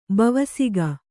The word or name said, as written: ಬವಸಿಗ